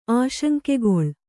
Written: ಆಶಂಕೆಗೊಳ್

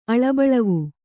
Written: ಅಳಬಳವು

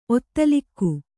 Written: ಒತ್ತಲಿಕ್ಕು

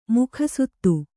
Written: ಮುಖಸುತ್ತು

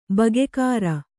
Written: ಬಗ್ಗರೆ

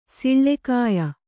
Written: ಸಿಳ್ಳೆಕಾಯ

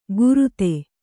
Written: ಕುರುದೆ

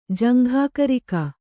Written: ಜಂಘಾಕರಿಕ

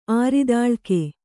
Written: ಆರಿದಾಳ್ಕೆ

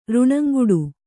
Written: ಋಣಂಗುಡು

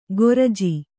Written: ಕೋರ್ಜಿ

